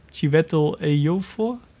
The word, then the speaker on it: Chiwetel Ejiofor: Uh, Chiwetel Ejiofor? – Ja.